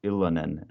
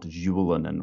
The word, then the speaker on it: Ylönen: his name is pronounced Ylönen and not Julenen or something, because I've heard both the pronunciations on TV.